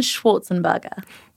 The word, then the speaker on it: Schwarzenberger: This week, I'm joined by Jordan Schwarzenberger.